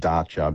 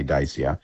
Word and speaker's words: Dacia: It's actually pronounced Dacia, but we say Dacia.